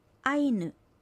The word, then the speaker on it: アイヌ: アイヌは人間という意味です。アクセントはアイにあります。